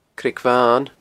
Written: Creag Bhan

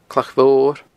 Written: Clach Mhor

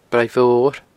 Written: braighmhor

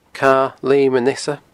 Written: Cadha Leum an Easa

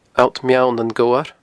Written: Allt Meall nan Gobhar

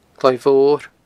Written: Cloi Mhor

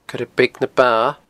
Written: coirebeagnaba

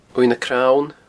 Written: uaimh nan crann

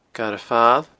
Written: Garadh Fail

